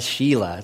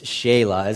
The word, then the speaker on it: Shelah: If you're looking at it like Sheila, like, why would you name your son Sheila? It's like a boy named Sue, right? Are you trying to make him a fighter or what? Like, it's Shelah is technically how you pronounce that.